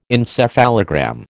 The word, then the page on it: encephalogram: American English